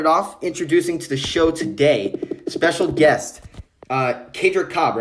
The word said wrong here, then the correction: Kadrick Cobbs: right? Kadrick, is that how you pronounce it? – Cobbs. Yes, Kadrick Cobbs. – Kadrick Cobbs, introducing to the show UTSA, University of Texas, San Antonio.